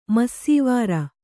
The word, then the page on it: ಮಾಸ್ವಾರ: māsvāra